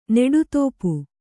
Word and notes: ನೆಡುತೋಪು: Kannada

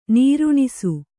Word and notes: ನಿರ್ನೇಸು: nirnēsu

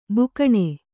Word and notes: ಬಕ್ಕನೆ: Kannada

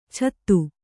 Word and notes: ಚತು: catu

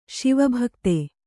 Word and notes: ಶಿವಭಕ್ತ: Śiva bhakta

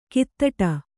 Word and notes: ಕೈತಟ್ಟು: Kannada

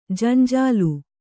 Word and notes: ಜಂಜಾಲು: janjālu

